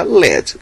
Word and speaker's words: Led: A pronúncia era uma bosta, então mudaram de Led, L-E-A-D, para LED, Led Zeppelin.